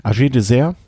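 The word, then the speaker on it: AG2R: Agé Désert, endlich ein Name, den ich muss ehrlich sagen, ich habe es jahrelang falsch ausgesprochen, ich habe es von dir gelernt, Chris.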